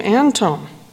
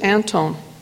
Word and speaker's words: Anthon: The family pronounces it Antone, which I believe is close to the Scandinavian pronunciation.